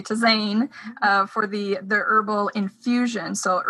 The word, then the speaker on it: tisane: Oh, tisane. It's tisane. It's a French word.